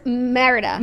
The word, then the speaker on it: Merida: It's giving Merelda. What do we say her name is? – Merida. – Merida.